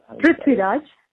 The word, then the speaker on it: Prithviraj: I actually really like, I always get his name wrong, Prithviraj? – Prithviraj? – Yeah, he's fantastic.